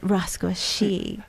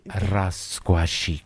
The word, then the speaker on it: Rascuachic: You have to say it, Rascuachic. – You have to roll the R's. Hey. Rascuachic.